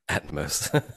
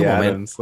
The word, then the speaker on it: Atmos: It's actually pronounced Atmos. – Probably typing in Dolby Atoms.